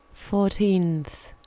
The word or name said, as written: fourteenth